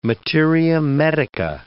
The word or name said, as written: materia medica